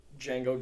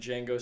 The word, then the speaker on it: Django: And Django says the D is silent, and the guy says, "I know."